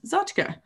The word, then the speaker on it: tchotchke: I saw assorted doodads, knickknacks, zachkas or as some people say, tchotchkes. Uh, I had been informed by my wife, Samly, that the proper pronunciation of tchotchke is zotchka.